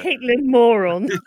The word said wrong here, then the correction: Caitlin Moran: Her name is spelled M-O-R-A-N. As far as I'm concerned, that's pronounced Moran, isn't it? Or Moran or something. What did you say it was? – I think she's Caitlin Moran, yes. – Caitlin Moran, okay, yeah, okay.